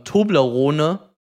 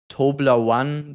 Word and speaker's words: Toblerone: Ja, natürlich dieses One am Ende sehe ich, aber Tobler One, das gibt es nicht, das ist kein englischer Begriff, also da spricht man hier Toblerone aus, der angesprochene Verkehrskreis.